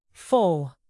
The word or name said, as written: foal